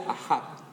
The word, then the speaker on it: Ahab: llamado Acab, aunque en hebreo se pronuncia Ahab.